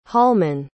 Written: holman

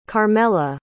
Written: carmella

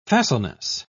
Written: facileness

